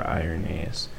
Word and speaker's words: Irenaeus: He also had a student, I don't know, I'm going to pronounce this wrong, Irenaeus, I-R-E-N-A-E-U-S.